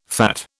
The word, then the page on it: fat: British English